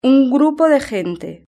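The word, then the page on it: un grupo de gente: European Spanish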